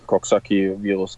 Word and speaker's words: Coxsackie: Ist das so richtig ausgesprochen? Ja, Coxsackie-Virus, genau ja, der hat halt im Endeffekt eine Entzündung im ISG im Rücken verursacht, wodurch ich diese Probleme hatte.